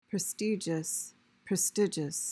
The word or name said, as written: prestigious